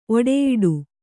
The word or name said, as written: ಒಡೆಯಿಡು